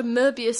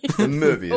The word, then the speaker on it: Möbius: Got a Möbius strip of grudges. – Möbius. – I said it wrong. It's not been a good evening for me. – Möbius.